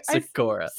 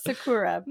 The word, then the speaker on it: Sakura: Is Naruto pronouncing Sakura wrong this whole time? – Oh, God. Oh. Is it Sakura Quest? It probably is. What did I just say? – Sakura. – Sakura. Perfect.